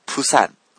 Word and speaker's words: プサン: これがじゃあどういうふうに言ったら変わるのかというと、 PUSAN というふうに書くと、韓国人はどういうふうに発音するかというと、プサン、プサンというふうに発音するんです。